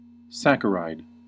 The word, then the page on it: saccharide: American English